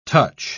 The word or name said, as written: touch